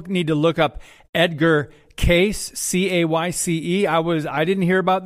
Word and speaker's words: Cayce: He pronounces his name Cayce, Edgar Cayce.